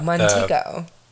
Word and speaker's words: Montego: Montego. Oh, like Montego. – Like the Montego Bay in Jamaica.